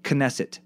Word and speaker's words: Knesset: Also, Knesset is pronounced Knesset. The K is not silent.